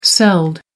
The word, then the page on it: celled: American English